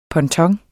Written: ponton

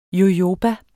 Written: jojoba

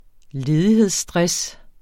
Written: ledighedsstress

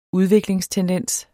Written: udviklingstendens

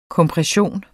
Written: kompression

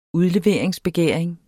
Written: udleveringsbegæring

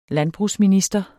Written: landbrugsminister

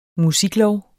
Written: musiklov

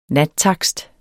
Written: nattakst

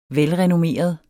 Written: velrenommeret

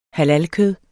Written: halalkød

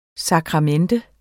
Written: SAKRAMENTET

